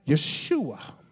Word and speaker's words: Yeshua: The name Jesus in the Hebrew language is pronounced Yeshua.